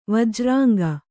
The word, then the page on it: ವಜ್ರಾಂಗ: vajrānga